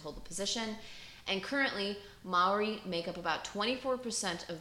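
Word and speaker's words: Māori: I had to actually look it up, and Maori, I believe is how you say it, Maori, so I apologize if I'm, like, fucking that shit up.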